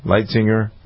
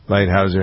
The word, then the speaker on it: Lighthizer: Both the delegates from the United States, Robert Lighthizer, is how you pronounce his name? – Lighthizer. – Lighthizer, yeah. Him too.